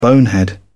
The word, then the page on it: bonehead: British English